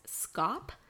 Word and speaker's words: Schaap: Sorry, I keep saying his name really weird because it's spelt S-C-H-A-A-P, but it's pronounced Scop.